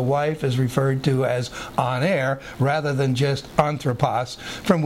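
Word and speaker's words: aner (ἀνήρ): Aner, almost like honor, but it's A-N, long E-R, aner.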